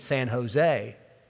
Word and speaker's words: San Jose: He said, "Look right there, San Jose, J-O-S-E. He said, "Well, yeah, but that's how it's spelled. That's not how it's said." He said, "It just sounds like an H. It's San Jose."